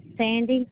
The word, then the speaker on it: Sandy: Oh, Sandy. – Can you spell that, please? – Yeah, S-A-N-D-Y.